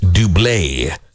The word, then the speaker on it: Double: The axe name is spelled like the word double. It is pronounced Dublé.